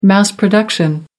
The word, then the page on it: mass production: American English